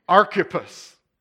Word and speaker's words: Archippus: Christy was saying I need to pronounce it Archippus. I looked it up this morning, Christy. It's Archippus. Archippus, but I'll say Archippus before the message is over.